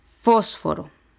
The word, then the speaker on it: fósforo: Fósforo, igual, tres sílabas. La antepenúltima es fósforo porque la última sería aro, la penúltima fo, la antepenúltima fos, fósforo.